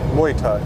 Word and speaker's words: Muay Thai: We're going to go to, how do you pronounce it? Mai Tai? – Muay Thai. – Muay Thai.